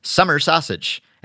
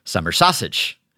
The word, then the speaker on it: summer sausage: Hence, it should be pronounced summer sausage and not summer sausage.